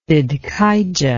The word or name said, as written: Zidkijah